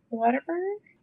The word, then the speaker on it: Whataburger: Whataburger? – I think it's really funny. – Did I say that wrong? – A lot of people say Whataburger, like it's water, and I'm like, it's what a burger.